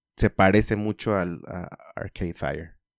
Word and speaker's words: Arcade Fire: ¿A ti te gusta Arcade, Fire? – Arcade...